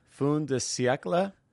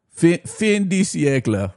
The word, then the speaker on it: fin de siècle: As early as 2000, academics Stephen Sutcliffe and Marion Bowman were commenting on how, this is where the quote begins, in our Fin de Siècle Emporium.